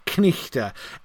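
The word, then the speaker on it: knight: knichte. You pronounce the K and the N, and you do the H on the GH, so the thing is pronounced knichte.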